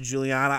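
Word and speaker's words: Giuliana: That's G-I-U-L-I-A-N-A. It's weird, but it's pronounced Juliana.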